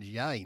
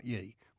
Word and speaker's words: Ye: Oh, it's pronounced yay, not ye. Well, Y-E to me, Steve-O, is ye, but if it's yay, fair enough.